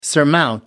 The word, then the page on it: surmount: American English